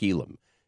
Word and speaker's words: Elam: I said, Mike Elam. She goes, how do you spell that? I said, E-L-A-M.